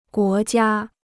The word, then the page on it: 国家: guó jiā